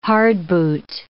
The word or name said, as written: hardboot